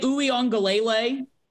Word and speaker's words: Uiagalelei: DJ Uiagalelei from Clemson.